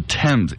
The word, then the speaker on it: Thames: It's the Thames. It's not the Thames. – Or Thames. – Thames. – Or Thames. – Thames. – Thames. – It's spelled, I mean, it looks like Thames, but it's Thames.